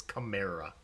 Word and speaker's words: Kamara: So they were just talking about this on Footballers this morning of how to properly say it. It's Kamara, according to him.